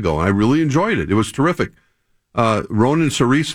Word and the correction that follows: Saoirse: that pronounce her first name? – Saoirse Ronan, yeah.